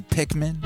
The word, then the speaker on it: Pikmin: Played some Pikmin. I always pronounce it with an extra I in the middle. There's no I in the middle, but I put one there. It sounds right to me.